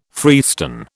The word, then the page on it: freestone: British English